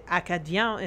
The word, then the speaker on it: Acadien: And that's the Acadien is how you say it in French.